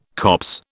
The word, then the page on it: copse: British English